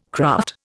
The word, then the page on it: craft: Indian